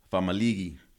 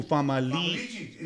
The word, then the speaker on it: Famaligi: Ooh, Famaligi. Isn't that well, that's what I was saying. So really? Going back to the podcast with Coldestone, I heard it was Famaligi, the proper way to say it.